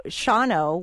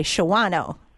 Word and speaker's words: Shawano: And there's also Shawano, which if you read it phonetically, you would say Shawano.